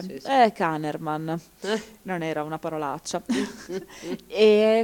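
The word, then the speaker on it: Kahneman: Si fa risalire alle ricerche degli psicologi Amon Tversky e Daniel Ankerman, che no, scusate, Kannerman.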